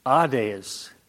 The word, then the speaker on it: Hades: But that would be pronounced Hades. The thing is, though, that in the Greek, there is no H. There's a way that they get the H pronounced, though, because they put a rough breathing mark in front of that first vowel.